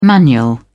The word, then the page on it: manual: British English